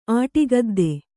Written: ಆಟಿಗದ್ದೆ

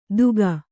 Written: ದೂಗ

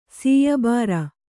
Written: ಸೀಯಬಾರ

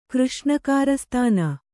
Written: ಕೃಷ್ಣಕಾರಸ್ಥಾನ

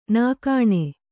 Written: ನಗಣ

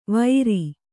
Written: ವೈರಿ

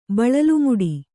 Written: ಬಳಲುಮುಡಿ

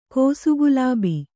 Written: ಕೋಸುಗುಲಾಬಿ

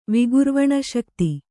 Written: ವಿಗುರ್ವಣಶಕ್ತಿ

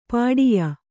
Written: ಪಟ್ಟೆಯ

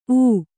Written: ಊ

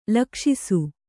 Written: ಲಕ್ಷಿಸು

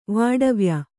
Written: ವಾಡವ್ಯ

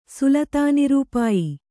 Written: ಸುಲತಾನಿರೂಪಾಯಿ

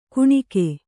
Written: ಕಣುಕ

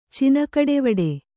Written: ಚಿನಕಡೆವಡೆ

